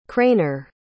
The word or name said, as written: craner